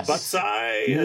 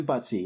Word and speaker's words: buttsi: Or is it Butsai? – No, it's fun to say. Am I saying that right? Butsy? Butsai? – Yeah, you're saying Butsy.